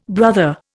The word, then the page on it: brother: Indian